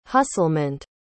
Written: hustlement

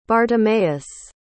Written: bartimaeus

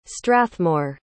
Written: strathmore

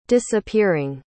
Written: disappearing

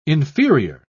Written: inferior

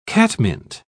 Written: catmint

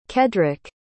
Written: Keddrick

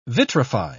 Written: vitrify

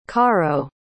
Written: caro

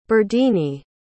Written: berdine

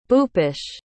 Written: bhoopesh

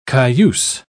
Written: cayuse

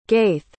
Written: ghaith

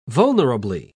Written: vulnerably

